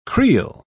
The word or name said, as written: creel